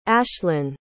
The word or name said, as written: ashlan